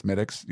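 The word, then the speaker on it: Smithwick's: It's spelled Smithwix, but it's Smittix. You just say that.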